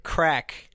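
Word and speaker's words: craic: Crack. That's pronounced crack. It's Irish, and I looked it up.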